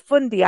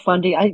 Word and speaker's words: Fundy: So you got to go to the Bay of Fundy. I like to say it. I'm not saying it right, am I? No. – Bay of Fundy. You're close.